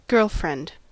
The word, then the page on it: girlfriend: American English